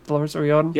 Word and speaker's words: Dolores O'Riordan: Have I said that right, Dolores O'Riordan? – Yeah, Cranberries, yeah.